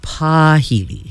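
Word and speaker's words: pāhili: So when we look at the word pahili, which is spelled P-A with the kahako or the makron over the A, H-I-L-I, we pronounce it pahili.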